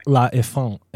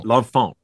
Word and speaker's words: L'Enfant: Oh, L'Enfant. – L'Enfant, right? – Yeah. Yeah. It's like in the word infant, but French.